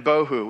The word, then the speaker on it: bohu: The two terms that Moses uses here are tohu and bohu, which is kind of fun because they both rhyme with tofu, which tofu in the original Hebrew means tasteless, right?